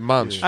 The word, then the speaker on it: Monch: You say it right, Monch. – I always think that it's Pharaoh Monch, and then I say it, and I'm like, is it Monk? – I've heard you say it.